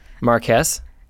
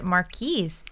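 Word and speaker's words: Marquess: That's how I pronounce it because it sounds like it rhymes. But when I was younger, I always pronounced it Marquise. Am I wrong? – I think it rhymes with yes.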